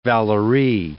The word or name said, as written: Valery